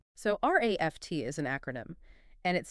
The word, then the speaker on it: RAFT: It's known as the RAFT framework. – And we should clarify, that's spelled RAFT, but pronounced exactly like the word raft.